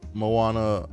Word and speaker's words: Moana: But anyway, so it was a Moana.